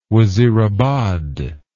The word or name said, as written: wazirabad